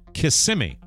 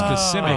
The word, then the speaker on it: Kissimmee: You pronounced it incorrectly. It's Kissimmee. It's Kissimmee. – I hate this. – Some people put the emphasis on the first.